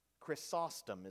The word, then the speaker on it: Chrysostom: Writing in the fourth century, uh, Christ, I think it's pronounced Chrysostom, is how it's pronounced, John Chrysostom.